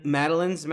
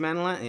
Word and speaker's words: Madeline: Madeline, I think I pronounced that right. – Mm-hmm. Madeline. – Madeline, yeah, that's what I thought.